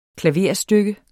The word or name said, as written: klaverstykke